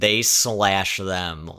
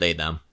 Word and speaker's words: They/Them: You're supposed to pronounce it, they slash them, because it's a killer, a slasher. – That's a very clear in this title. I couldn't pronounce it any other way, you know? – Everyone I've talked to just call it they, them.